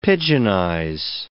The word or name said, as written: pidginize